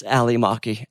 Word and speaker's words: Ally Maki: It's Ali Maki.